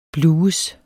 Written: blues

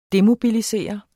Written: DEMOBILISEREDE

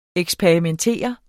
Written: eksperimentere

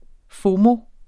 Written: FOMO

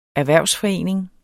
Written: erhvervsforening

